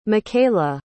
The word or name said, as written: mckayla